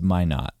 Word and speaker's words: Minot: How do you say it? – I think it's Minot.